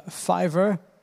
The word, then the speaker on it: Fiverr: donc, euh, Fiverr, f i v e r rcom ça s'écrit, euh, le nom c'est comme ça parce que la plupart des trucs sont pour 5 dollars.